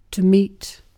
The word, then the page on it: meet: British English